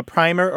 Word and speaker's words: primer: The word's got two pronunciations. So a primer or a primer, P-R-I-M-E-R, is a book that teaches you something.